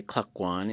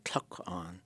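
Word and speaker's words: Klukwan: And some people say Klakwan, and you could also say Tlakwan.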